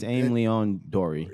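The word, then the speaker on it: Aimé Leon Dore: Um, ALD for short, AIM Leon Dore. I'm sure I'm butchering the name it's, to a certain yeah, it's, extent. It's AIM Leon Dory.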